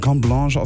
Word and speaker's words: Carte Blanche: Kommt von Topic und Cornblanche. Das sind Franzosen. Es wird Carte Blanche geschrieben, aber Cornblanche ausgesprochen.